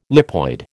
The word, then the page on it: lipoid: American English